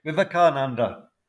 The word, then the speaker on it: Vivekananda: Vivekananda.